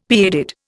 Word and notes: pigheaded: Indian

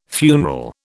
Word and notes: funeral: British English

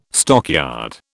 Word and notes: stockyard: British English

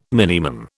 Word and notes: Min: British English

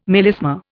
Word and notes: melisma: Indian

